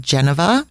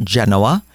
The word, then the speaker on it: Genova: It's about halfway between the city of Genova, or as the English pronounce it, Genoa, and Cinque Terre.